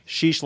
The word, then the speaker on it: Sheesh: Sheesh like geese. – Oh, sheesh. Not sheese. Okay, Patrick Sheesh.